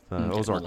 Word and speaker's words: Ozark: Ozark like Mountains. Ozark. Okay. Sorry, what? – Ozark or something. – Ozark? – Yeah, well, it's OZ, so. – Yeah, no, it's definitely Ozark.